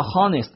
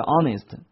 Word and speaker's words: honest: 如果人们按照和传承这个习惯，就会将很多本来也发音的字母的 h 也会消失去书写，所以呢省略掉了，导致于这个 h 字母发音。我们看到很多例子，比如说小时不叫做 hour 叫 hour，比如说诚恳不叫 honest 叫 honest。